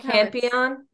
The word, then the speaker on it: Campione: It's spelled Campion with a C. – C A M P I O N E. – It's like champion, but with no H.